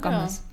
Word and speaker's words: Gomez: Das habe ich jetzt nicht gemacht, aber das ist halt die wichtige Nachricht der Woche, dass Justin Bieber und Selena Gomez wieder, spricht man die eigentlich so aus? Ja, schon, ne? Gomez. Ja.